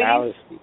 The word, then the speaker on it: Allesee: It's Kevin Alafi. – Actually, Alice. – Alice. – Yeah, Alice.